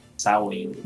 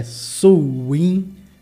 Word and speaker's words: Samhain: Na realidade, é, se escreve Samhain. Uhum. Já teve pronúncia que é Suwin. Eu procurei lá, tem uma discussão etimológica de uma galera de linguística, cara, tudo brigando. Mas sim, o, o, consenso até então é que é Samhain.